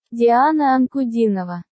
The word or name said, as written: Diana Ankudinova